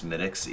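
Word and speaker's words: Smithwick's: But I think mostly it's just the fact that I like that I know how to pronounce Smittics, even though it's spelled Smithwicks.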